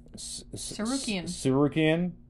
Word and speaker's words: Tsarukyan: Demir Esmagulov is facing Arman Tsarukyan. – Yeah, that's right. That is how you pronounce that.